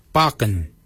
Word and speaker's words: baginy: For beard, we say baken. Baken is spelled B-A-G-I-N-Y.